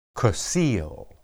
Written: kesil

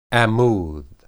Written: ammud

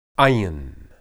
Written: ayin

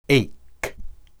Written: eyk

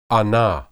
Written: anah

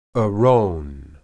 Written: eron

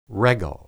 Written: regel

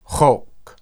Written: hoq

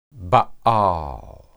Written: baal

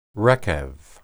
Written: rekeb

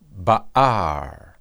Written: baar

